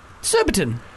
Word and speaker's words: Surbiton: We've got Phoebe from Surbiton. – Phoebe. – Am I saying that right? – Yeah, Surbiton's perfect.